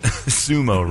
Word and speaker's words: sumo: You said sumo, somu. You've said everything but sumo.